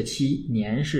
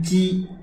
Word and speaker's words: 期: 七是日期的七，年是这个。呃，年月的年啊，年岁的年，这个七呀，应该读成鸡，就是我刚才读的时候呢，应该是不对了，应该说鸡年敌必至，就是啊，表示一年就十二个月的这么个意思。